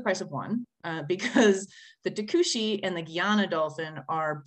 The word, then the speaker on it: Tucuxi: And the Tucuxi, right? That's how it's said, mm-hmm. right? It's a T-U-C-U-X-I.